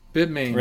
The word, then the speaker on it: Bitmain: Bitmain, right? – Yeah.